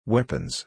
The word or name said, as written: weapons